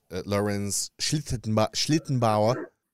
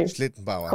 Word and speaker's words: Schlittenbauer: They found Lorenz Schlittenbauer. Am I saying that right? – Perfectly. – Schlittenbauer.